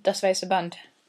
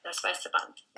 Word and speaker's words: Das weiße Band: You'll know it better than me, but it's Das Weiße Band or something like that. – Oh yeah, Das Weiße Band. – Das Weiße Band.